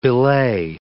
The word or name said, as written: belay